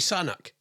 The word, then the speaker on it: Sunak: He called him Rishi Sunak. Sanuk. It's Rishi Sanak.